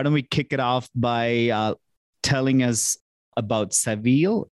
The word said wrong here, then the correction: Savills: I like to say travels with Savills. That's a good way to remember the pronunciation of the brand.